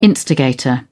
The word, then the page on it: instigator: British English